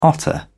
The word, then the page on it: otter: British English